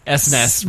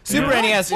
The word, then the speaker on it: SNES: referred to it as the SNES, right, or something like that. – The SNES, which is like two orders of magnitude wrong. – Who ever says that? – But I think I go on a diatribe of how Super Nintendo is the only correct way to say this. – I mean, I think people that say SNES are monsters. – Super NES is okay.